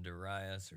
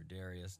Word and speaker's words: Darius: And it is under Cyrus and Darius, or Darius, depending on your pronunciation, that Ezra and Nehemiah are allowed to return back to Jerusalem and begin rebuilding.